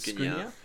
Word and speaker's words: Skriniar: And then obviously they've got, you know, standout player is Milan Skriniar. I don't know how to pronounce it. – Skriniar.